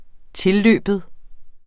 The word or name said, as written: tilløben